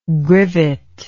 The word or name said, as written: grivet